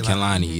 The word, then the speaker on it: Kehlani: Is it Kalani? That how you pronounce Kehlani. – It? Kehlani. Yeah, she's dope as shit.